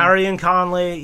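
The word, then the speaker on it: Gareon Conley: So, Gary and Conley, is that how you pronounce his name? Gary and Conley, yeah.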